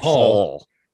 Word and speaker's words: Hall: I mean, guess they call it's him Hall. It's like Hall, but with like a short A. – Did his ancestors fail spelling? – Hole. – It's kind of like between Hall and Hole.